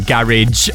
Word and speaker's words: garage: Garage. House or garage. House music for the win. And if you are pronouncing it, it's garage. I won't hear any other pronunciation.